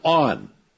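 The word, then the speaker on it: on (ἄν): And they don't tell you that there's one word in the Greek which is not translated, and it's the Greek word on. A-N, pronounced on, which means there are circumstances and qualifications to the statement often as.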